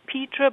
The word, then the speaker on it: Petre: Is it Peter or Petre? – I think it's Petra, but... – Petra, okay. – You know, one of those things, nobody really knows because names can be pronounced as the family kind of likes. – Sure. – But I call him Lord Petra.